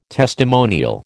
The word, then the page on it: testimonial: American English